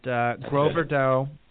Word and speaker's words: Gros Verdot: And it looks like something approximating Carmenere and Gros Verdot. Did I say that right, David? – Yes, sir.